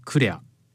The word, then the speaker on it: CLR: 例えばサーヤの場合はクレア、 CLR というあのアルファベット 3 文字でクレアと読みます。